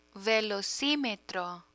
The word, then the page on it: velocimetro: Spanish